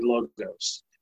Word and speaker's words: Logos: The very Logos, however you pronounce it. I looked it up. It's actually Logos.